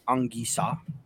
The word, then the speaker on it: Anguissa: The proper pronunciation of his name is Anguissa.